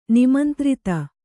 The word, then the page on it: ನಿಮಂತ್ರಿತ: nimantrita